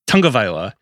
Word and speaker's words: Tagovailoa: I have Tua Tagovailoa as my backup. And sorry, I butchered the name. Tagovailoa.